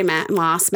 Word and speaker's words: Maas: Because I like Sarah J. Maas, that's how you say it.